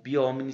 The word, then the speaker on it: Biomni: o biomini se escreve B-I-O-M-N-I, biomini.